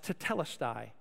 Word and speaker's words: tetelestai: Now, this little phrase, "it is finished," is actually a single Greek word that's pronounced tetelestai.